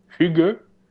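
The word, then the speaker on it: hygge: Euh, ben, il, nous proposait de parler du hedge, qu'il faut prononcer Hugue.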